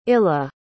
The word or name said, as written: illa